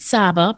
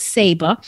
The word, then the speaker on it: Saba: A lot of people want to pronounce it Saba, but it's called Saba.